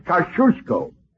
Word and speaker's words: Kosciuszko: In Poland, we say his name Thaddeus Kosciuszko. But in America, Thaddeus Kosciuszko is fine. – Jenna, please. – Thank you, young lady. Thaddeus Kosciuszko. How's that? – Very good. You say it like you're going to sneeze, and you got it.